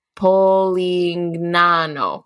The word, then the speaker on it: Polignano: Polangina. – It. No, it's they're in Polangina, I think. Let me see. It was on here. – Uh, Polignano. Polignano. That's where they are. Perfect pronunciation. Thank you.